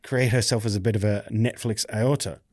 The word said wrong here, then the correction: auteur: Aorta is the thing in your heart. Yeah. Auteur. I can't say it properly. A-U-T-E-U-R.